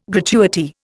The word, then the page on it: gratuity: Indian